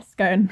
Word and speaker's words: Scone: Yeah, Scone. – Or Cassidy. – Not to be confused with Scone the food.